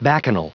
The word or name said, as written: bacchanal